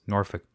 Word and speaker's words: Norfolk: I suppose the idea here is that a lot of people mispronounced Norfolk to be Norfolk.